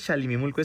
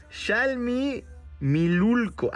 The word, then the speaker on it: Xalmimilulco: No es ni siquiera Xalimimulco, es Xalimimulilco. ¿Ok? Si lo estoy pronunciando bien, a ver. Xalmimilulco. Ahora sí, está perfecto.